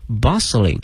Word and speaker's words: bustling: 哎，这里面看一下这个海口哈，这个马路的前面跟了一个形容词叫 bustling， B-U-S-T-L-I-N-G， bustling。同时注意一下，这个 T 是不发音的